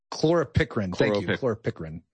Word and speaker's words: chloropicrin: particularly perchlorin. Did I pronounce that right? – Chloropicrin.